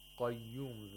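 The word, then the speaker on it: Qayyum (القيوم): Huwa al-hayyu al-qayyum. Qayyum. The qaf in the word al-qayyum has tafkhim because it's a letter of isti'la. – So it should be pronounced thickly.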